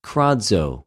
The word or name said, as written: κραζω